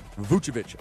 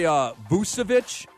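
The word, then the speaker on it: Vučević: Vucevic. I used to say Vucevic, and I literally got corrected by Orlando PR during a summer league game.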